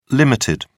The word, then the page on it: ltd.: British English